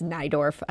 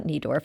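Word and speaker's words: Neidorff: And I should mention that CEO, that's Nydorf, uh, not Niedorf. I do too much reading of the news, not enough listening well, to I it.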